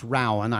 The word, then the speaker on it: Rowan: It's pronounced Rowan. I learned Rowan, by going down there.